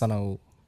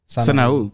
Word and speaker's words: Sanaul: It's actually pronounced Sanaul, but, you know. – Sanaul? – Sanaul.